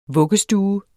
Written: vuggestue

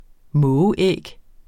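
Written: mågeæg

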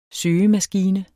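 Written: SØGEMASKINE

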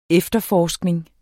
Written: efterforskning

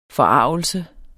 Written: forargelse

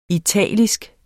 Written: italisk

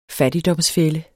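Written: fattigdomsfælde

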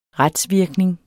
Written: retsvirkning

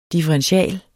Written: differential

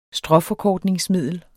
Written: stråforkortningsmiddel

